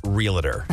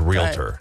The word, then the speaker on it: realtor: It's not realtor. It's not realtor. There's two syllables. It's realtor.